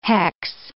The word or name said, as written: hex